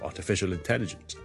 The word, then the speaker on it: artificial intelligence: 所以咧，artificial 咧其实后面有个 ism 嘅，所以 artificial 你咁楞埋落去，楞埋落去 intelligence 咧，intelligence 系 in 开头啦，vowel 开头，所以我通常会连埋前面嗰个音个 ism，所以我读成咧 artificial intelligence l i n 咁啊，所以 artificial intelligence。